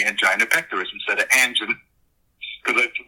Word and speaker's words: angina: And the medical director on the set kept telling me, it's angina. And I said, well, I've never heard it pronounced angina. I've always heard it pronounced angina. No, it's angina, angina.